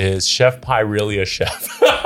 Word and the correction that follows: Chef P: Oh, Chef P. Pronounced Chef P. Okay, so he's saying pie.